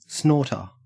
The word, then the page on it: snorter: Australian English